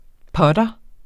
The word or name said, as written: putter